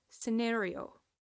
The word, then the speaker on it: scenario: Moving on, we have the next word, and that is scenario. Scenario. And this word, once again, it's pronounced differently in American and in British English. This is not to say that American and British English are the only types of English,